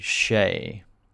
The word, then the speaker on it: Hsieh: Former Zappos CEO Tony Hsieh. We have figured out how to pronounce his name.